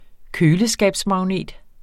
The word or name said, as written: køleskabsmagnet